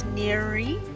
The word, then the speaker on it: Nouri: And that's Neri. That's N. How do you spell that again? It's N-O-U-R-I. N-O-U-R-I. – Just think of nourish. Think of nourish. Drop the S-H.